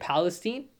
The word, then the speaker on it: Palestine: federal context, but if I was a resident of that area, if I was a resident of East, how do you say it? Palestine? Palestine. Mm-hmm.